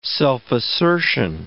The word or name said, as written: self-assertion